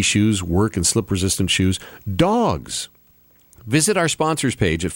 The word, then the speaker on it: Dawgs: This is where Dawgs comes in. D-A-W-G-S, Dawgs, is how you say it. It's how you spell it, too.